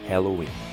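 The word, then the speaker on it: Helloween: Na verdade, a diferença é que no lugar da letra A, temos um E, mas a pronúncia é a mesma, Halloween.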